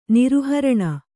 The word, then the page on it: ನಿರುಹರಣ: niruharaṇa